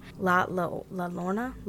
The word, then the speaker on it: La Llorona: I don't know if I say that right, because it's Spanish. La Llorona. Thank you. – Two L's makes a Y.